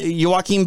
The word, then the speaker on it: Joaquin: Like I can't even say his name, Joe Quinn. – Quinn. Oh, uh Joaquin Buckley.